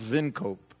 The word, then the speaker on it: Wynkoop: The name Wynkoop, which I have anglicized, somebody along the way anglicized the name, is Dutch. And it's probably best pronounced Vinkoop.